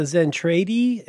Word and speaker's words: Zentradi: Um, can you pronounce it, that word? Was feel, that word? Uh, Zentradi. It looks anime. Yeah, you got it.